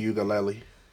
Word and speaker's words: Uiagalelei: DJ Uiagalelei.